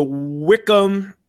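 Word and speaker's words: Wycombe: And then your last match is the Wickham Aston Villa replay winner will take on the Manchester City. – Yeah, the Connor Wickham Wanderers, even though it's spelled differently, it sounds the same.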